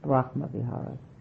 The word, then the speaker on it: Brahma Vihara: Brahma Vihara.